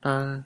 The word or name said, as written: pal